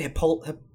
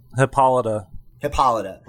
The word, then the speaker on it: Hippolyta: Hippolyta. – Hippolyta.